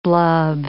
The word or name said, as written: blub